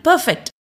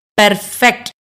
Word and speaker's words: பெர்ஃபெக்ட்: அதே மாதிரி பெர்ஃபெக்ட் நாம பேசும்போது பெர்ஃபெக்ட் அப்படின்னு ஆருக்கு அழுத்தம் கொடுத்து பேசுவோம். நேட்டிவ் ஸ்பீக்கர்ஸ் பெர்ஃபெக்ட் அப்படின்னு ப்ரொனவுன்ஸ் பண்ணுவாங்க. பொதுவாகவே ஆங்கிலத்தில் ஆர் சொல்லுக்கு ரொம்ப அழுத்தம் கொடுக்கறது இல்ல.